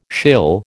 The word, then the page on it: shill: American English